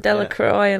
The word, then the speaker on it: Delacroix: It's Louisiana pronunciation, Delacroix and all that lot.